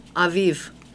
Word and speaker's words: Aviv: Okay, so we're a Bible study center, so I looked at the Hebrew pronunciation. I was curious. But it's actually Abib. Abib, or Hebrews actually say with a V, Aviv.